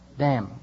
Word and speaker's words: Dam: I think they pronounce it Dam, which is slightly different from Dam.